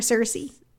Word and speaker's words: Searcy: Is that how you say it? Searcy or Searcy? – Searcy.